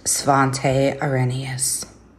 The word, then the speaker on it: Svante Arrhenius: I'm sorry, savant. I am absolutely mispronouncing your name. – His name is pronounced Svante Arrhenius.